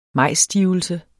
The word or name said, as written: MAJSSTIVELSE